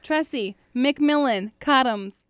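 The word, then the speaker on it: Tressie McMillan Cottom: Just go for it, Tressie McMillan Cottoms.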